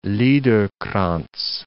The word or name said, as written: Liederkranz